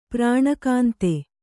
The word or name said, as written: ಪ್ರಾಣಕಾಂತ